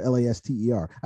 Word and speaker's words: Laster: Yeah, you can find me at Sir Lance Laster on all my social medias, and that's Laster, Laster. I think you might have gotten away with adding an extra syllable to my name. – Did I say Lassiter? – I think you did. – I did. Yeah. – So just one S in there.